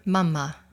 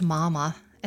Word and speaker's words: mama: We called our mother Mama, not pronounced Mama in the American way, but Mama, as it sounded in Iceland, with a pause on the combined M's.